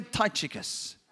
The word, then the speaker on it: Tychicus: He says, Tychicus. Everyone say Tychicus. Almost sounds like a kung fu move, like if you were, oh, Tychicus. He says, Tychicus will give you a full report about how I'm getting along.